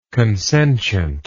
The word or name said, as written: consentiently